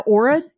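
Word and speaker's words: Oura: Aura ring? – Yeah, you're saying it right.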